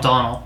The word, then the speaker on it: Domhnall: is it Donald Gleason? – It's Domhnall? It's spelled Domhnall, but the whole panel they were just calling Donald.